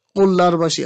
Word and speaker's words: قوللرباشی: این تشکیل نیروی 40,000 نفره قوللرباشی ها یا همون غلامان شاه، قوللرباشی ها دیگه تلفظش سخته، من دیگه دو تا له میذارن، آره قوللرباشی ها، یه هر وقت خواستی شما رو صوت اصلاح کنید.